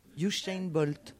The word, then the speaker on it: Usain Bolt: Usain Bolt. – Usain Bolt. Moi, je prononce avec l'accent anglais.